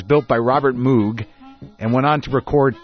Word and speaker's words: Moog: It's Moog. – Moog.